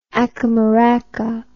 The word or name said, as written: ackamaraka